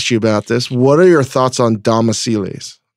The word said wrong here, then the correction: Domilise's: Or even how to pronounce it right? Uh, Domilise's. Domilise's.